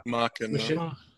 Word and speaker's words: Machinima: Is it Machinima or Machinima? – Machinima. – Machinima. – There you go.